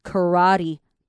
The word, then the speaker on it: karate: 我们这边 as 跟 a 把它连在一起 as a karate punch. 好，karate 前面这个 k a 我们把它念成 k k k. 好，k k k 后面呢，我们后面那个部分 r a 我们就把它念 ra karate karate 是那个注音符号拼起来有点像的跟 e 这样 karate.